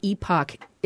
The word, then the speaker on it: epoch: First, Dr. Everhan, as a professor of environmental studies, can you tell me what an epoch is? And am I pronouncing it correct? – Yeah, you are.